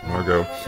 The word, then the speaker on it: Margot: I don't think he pronounced the T as Margot. – Margot.